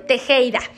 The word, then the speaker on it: Tejeda: Bueno, pues, como la doctora Melisa Tejeda. Van a escribir mi nombre, Melisa con doble S y mi apellido Tejeda se escribe T de Tito, J de jamón y la letra D de dedo, Tejeda.